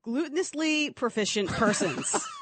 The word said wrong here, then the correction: gluteously: I said that wrong. Not glutinously. Gluteously.